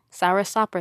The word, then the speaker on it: soursop: I had a, is it pronounced soursop? Soursop? – Yeah. – Soursop or sock? – Soursop.